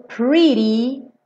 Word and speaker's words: pretty: Cuidado aqui, ó. Pre. Não pode ser pre. Porque a gente tem um R e o R em inglês é enrolado, né? Como importa, per, né? Então, pre. E aí, aqui, como tá pintadinho de azul, ri-ri-ri, ri-ri-ri. Igual na palavra guri, pretty, tá?